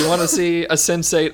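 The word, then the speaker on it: Sense8: I thought it was pronounced sensei. I feel like an ass. No. – Yes. It is pronounced sensate.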